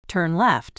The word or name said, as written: turn left